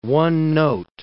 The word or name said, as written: one-note